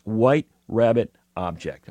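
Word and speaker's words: WHT RBBT OBJ: It's WHT period, RBBT period, OBJ, pronounced White Rabbit Object.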